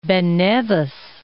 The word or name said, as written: Ben Nevis